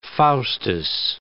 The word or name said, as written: Faustus